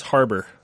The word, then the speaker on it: Harbour: Yeah, it's Harbour.